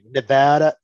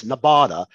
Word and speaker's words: Nevada: My only thing is that I'm afraid of is that uh, being a Yankee by way of the South, I might mispronounce Nevada. I might say Nevada, Nevada, it's Nevada.